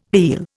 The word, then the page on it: heal: Indian